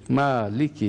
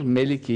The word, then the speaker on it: Maliki: Maliki Yevmiddin. Maliki Yevmiddin. Malik sahip demek. – Evet. – Bu Melik diye de okunur. Meliki Yevmiddin diye de.